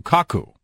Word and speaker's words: Kaku: You're talking about Michio Kaku. – Kaku, right, yeah. Correct. I mispronounced his name.